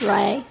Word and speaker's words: Ray: And I am Olive Ray. Just R-A-Y. Sail thinking, how do you spell that? It's just R-A-Y.